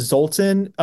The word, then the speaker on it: Zoltan: Am I saying that right? – Yeah, Zoltan. Okay.